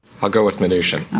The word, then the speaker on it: Mnuchin: Stephen, not Steve, Mnuchin, not Mnuchin or Mnuchin or whatever people said.